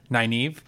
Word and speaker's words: Nynaeve: It's been pointed out to me that I mispronounced the name of a very major Robert Jordan character. Whose name is actually pronounced? – Nynaeve.